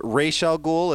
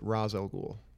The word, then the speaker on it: Ra's al Ghul: Ozzy is Ra's al Ghul. Let's just say it correctly while we're on our show, please. – I mean, I think Neil Adams does pronounce it Ra's al Ghul.